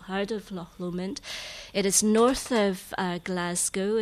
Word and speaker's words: Loch Lomond: You don't pronounce the CH, it's just Loch Lomond.